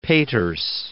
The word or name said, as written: Peters